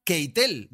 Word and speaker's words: Keitel: Porque has pronunciado muy, muy bien. Keitel, te recuerdo que nos enseñó a pronunciarlo Rodrigo sí.